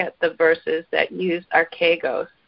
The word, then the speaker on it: archegos: The word captain in the Greek is spelled A-R-C-H-E-G-O-S, archegos.